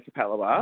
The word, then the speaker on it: Capalaba: And it took me ages to learn how to say Capalaba. – Oh, well, it might not be my show you're enjoying then. – Because that's how, if you look at it, it's C-A-P-A-L-B-A, Cap-L-Laba.